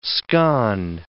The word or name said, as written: scone